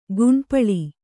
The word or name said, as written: ಘನೋಪಲ